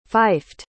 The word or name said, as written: fifed